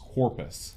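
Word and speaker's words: Corpes: It's I remember reading that his name is pronounced Corpus.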